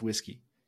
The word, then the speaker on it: WSGI: Like, my favorite abbreviation is, I think, the WSGI server. – Yeah. – Which is pronounced whiskey.